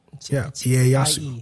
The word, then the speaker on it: Ieyasu: I and the E is Ie. – Ieyasu. That's how you're supposed to pronounce it.